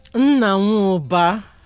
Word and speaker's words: Nnanwubar: Nnanwubar. With the R. So there's a tilt a little to the R.